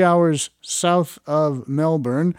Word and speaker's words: Melbourne: I always pronounced it Melbourne, but when I was there, I was told, no, it's Melbourne.